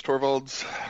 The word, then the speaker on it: Torvalds: Torvald. – Yeah, Linus Torvalds.